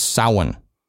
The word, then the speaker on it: Samhain: In Gaelic, the research I've done, it's Samhain. It's like Sowin. – Yeah. – That's how you would pronounce it.